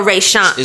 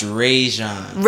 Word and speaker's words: Rajon: Is it Rajon or Rayshon? – It's pronounced Rajon.